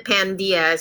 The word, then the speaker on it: Pandia: We like to pronounce it Pandia.